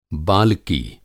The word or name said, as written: बालकी